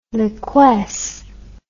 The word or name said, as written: liquesce